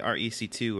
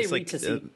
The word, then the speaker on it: RE2C: I always say REC2 or whatever. – I say RE2C, but yeah.